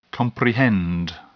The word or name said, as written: comprehend